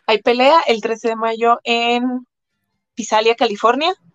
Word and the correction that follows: Visalia: No sé cómo se pronuncia. Pisalia. Bueno, en Visalia, California, este, voy contra una chica que es zurda y pues va a ser, nunca he peleado en ese peso tan pesada.